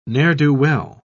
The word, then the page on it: ne'er-do-well: British English